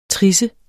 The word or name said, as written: trissen